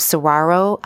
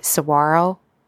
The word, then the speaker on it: Saguaro: David graduated from Saguaro High School. I think I said that right. Saguaro. It's a cactus, I think. There's a G in it, which is really trying to play tricks with my mind.